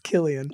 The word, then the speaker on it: Cillian: Cillian. – It's Killian.